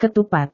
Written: ketupat

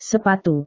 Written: sepatu

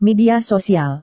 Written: media sosial

